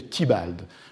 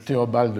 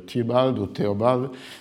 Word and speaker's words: Theobald: Il écrivait le nom propre comme s'il devait se prononcer Thibald. Mais rien n'indique que nécessairement Theobald lui-même prononçait son nom de cette façon.